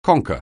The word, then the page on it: conker: anglais